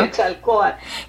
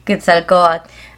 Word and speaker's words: Quetzalcóatl: Yo sí me fui a mirar quién era este Quetzalcóatl, ¿cómo lo estoy pronunciando mal, no? – Quetzalcóatl. – Quetzalcóatl.